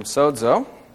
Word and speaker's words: sozo: From sozo, S-O-Z-O, long O again.